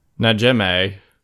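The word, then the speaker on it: Najimy: Our cast consists of Sarah Jessica Parker, Bette Midler, Kathy, gonna mess her name up, Najimi? I don't know how to say it. – Najimi.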